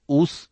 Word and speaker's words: ഊസ്: ഹസ് എന്നത് ഊസ് എന്ന് ഉച്ചാരണം ചെയ്യപ്പെട്ടിരുന്നു. പുരാതന പട്ടണമായ ദമസ്കാസിന്റെ സ്ഥാപകനാകുന്നു ഈ ഊസ് എന്ന് ജോസീഫസ് പറയുന്നുണ്ട്.